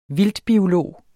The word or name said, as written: vildtbiolog